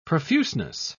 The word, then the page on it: profuseness: British English